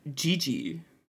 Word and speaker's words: Didi: Wait, it's spelled Didi, but it's pronounced Gigi.